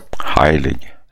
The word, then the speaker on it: Heilig: Hur man uttalar hans namn, det har jag aldrig förstått faktiskt. Svensk eller tyskt uttal? – Heilig.